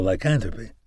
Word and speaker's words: lycanthropy: "Well, they've got you spouting off about the most detailed aspects of clinical lycanthropy.